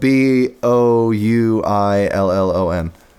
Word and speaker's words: Bouillon: It's spelled B O U I L L O N.